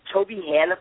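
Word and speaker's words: Tobyhanna: So you're actually from, is it Toby Hanna, Pennsylvania? – Yeah, a.k.a.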